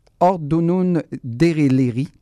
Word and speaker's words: Ordunun Dereleri: Et en attendant le, la sortie officielle de l'album, on vous propose la pièce, j'espère de bien le prononcer, Ordunun Dereleri ton turc est excellent.